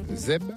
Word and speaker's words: zeb: Zeb, comme herbe, à faire. – Zeb. – Parce qu'il est chargé en fer aussi. – À Zeb à faire. – Ouais, ouais. On prononce pas les R ici.